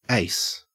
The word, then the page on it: ace: Australian English